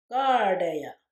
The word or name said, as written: gaardeya